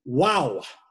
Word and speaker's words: Waw: That is the Wow. Sometimes it's spelled W-A-W, which is okay. We just need to understand with Hebrew, the W sounds makes also a sound of Wa with a U.